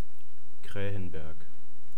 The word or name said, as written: kraehenberg